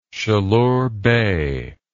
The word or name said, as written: Chaleur Bay